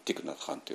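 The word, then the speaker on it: Thích Nhất Hạnh: でそれがベトナム語でティクナカンと読むんですけども、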